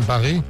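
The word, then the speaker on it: Paris: París. Se come la S, las letras del final se las comen. – Oye, ¿y qué hace esta oyente española valenciana más concretamente en París? – Pues cosas del amor.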